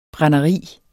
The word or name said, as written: brænderi